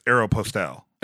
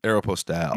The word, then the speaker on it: Aeropostale: Yeah, I think it's Aeropostale. – Aeropostale. – Mm-hmm. Airmail. – Must be Italian. – Yeah, airmail. – Postal. – It is. Aeropostale. It's airmail, man.